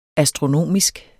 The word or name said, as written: astronomisk